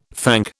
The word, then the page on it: thank: British English